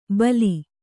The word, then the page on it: ಬಲಿ: bali